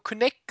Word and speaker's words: Kinect: Hab Kinect oder so gesagt, aber auf der Konferenz haben sie alle einfach nur Connect gesagt.